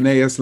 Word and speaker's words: Linnaeus: Carl Linnaeus? – Yes. – Is that correct? – Linnaeus. – I didn't butcher it.